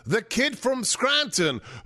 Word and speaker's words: Scranton: It's not a difficult word. It's got two syllables, scran-ton.